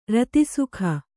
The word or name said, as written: ರತಿಸುಖ